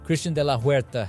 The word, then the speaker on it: Christian de la Huerta: Christian de la Huerta has also been a speaker at many conferences and events, and he has traveled all over the world.